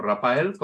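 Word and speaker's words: Raphael: Antes de daros esa sorpresa, pues escuchar a Tom Jones y Rafael, o Rafael, como algunos prefieren pronunciar, en este tema que se titula,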